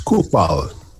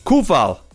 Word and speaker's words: Coufal: It's Coufal. Coufal. Coufal. Sensational.